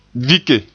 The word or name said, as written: vike